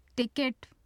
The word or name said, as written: tikat